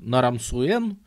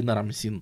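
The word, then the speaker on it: Нарам-Суэн: И, по сути, все, чем заняты его дети, бедные, и особенно его внук Нарамсуэн, ну, или нарамсин. Нарамсин проще произносить, поэтому я буду говорить нарамсин.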